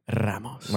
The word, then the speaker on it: Ramos: How would you pronounce your name? – You want me to pronounce it like Spanish accent? – Traditionally, like? Yeah. – Francisco Ramos.